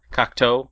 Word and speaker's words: Cocteau: Because I feel Cocteau? – Like Cocteau.